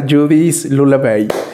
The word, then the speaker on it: Judys Lullaby: Démosle una bienvenida a Judith Lula Bay.